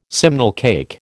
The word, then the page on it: simnel cake: American English